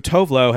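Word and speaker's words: Tove Lo: So Tovlo has just like resigned to being called Tovlo, but technically, it's Tove Styrke is the other Tove. And it should be like Tuvalu is how Tovlo is actually pronounced.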